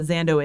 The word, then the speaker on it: Xando: I don't know if you know what Zando is, but it was spelled like X-A-N-D-O. And some people pronounced it X-A-N-D-O, and some people just said Zando.